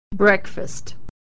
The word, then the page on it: breakfast: e